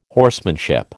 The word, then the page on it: horsemanship: American English